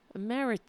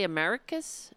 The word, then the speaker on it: emeritus: Did I say it wrong? See, and now I'm being incredibly self-conscious. No, I said it right. Emeritus. Did I say Americus? Emeritus.